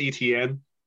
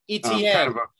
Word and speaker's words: Etienne: Yeah, it's Travis Etienne. – Etienne. – It's like a news network.